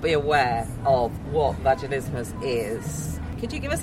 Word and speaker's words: Vaginismus: I've been practicing, and the way I have learned to say it is fast. Vaginismus. – Vaginismus. – It sounds like a dinosaur. – It rhymes with Christmas, so remember that.